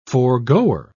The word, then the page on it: foregoer: British English